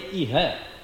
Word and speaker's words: iha: If the word is Iha, is a short A, Iha. Yeah. Iha means here, in this place.